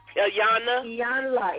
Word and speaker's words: Iyanla: How do you say her name? – Iyanla.